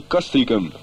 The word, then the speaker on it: Castricum: Well, I know, but in Holland we say Castricum.